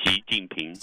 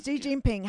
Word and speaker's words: Xi Jinping: Xi Jinping. – Xi Jinping, how uh, he comported himself.